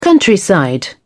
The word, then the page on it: countryside: British English